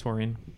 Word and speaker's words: taurine: Yeah, we're going to go with taurine. – Yeah, not 100% sure how it's pronounced, taurine or taurine, but taurine. – Taurine is correct.